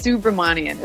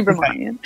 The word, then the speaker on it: Subramanian: It's Subramanian. It's got a lot of letters and vowels. Yeah. Subramanian.